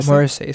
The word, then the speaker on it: Morrissey: Is that how you say his name, CJ? Morrissey? – I think that's a pretty good pronunciation. – Yeah. Morrissey.